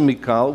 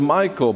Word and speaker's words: Michal: I pronounce it Michal, which is the way the Hebrews would pronounce it. Most of you might pronounce it Michael,